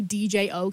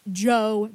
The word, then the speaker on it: Djo: I cannot say Joe right off the rip. I have to say DJO because that's the way it's spelled. And I've watched interviews. It's Joe. Like it's pronounced like Joe Keery. That's the artist's name, like his real name.